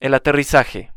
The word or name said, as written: el aterrizaje